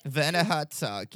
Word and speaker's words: Werner Herzog: Werner Herzog, yes.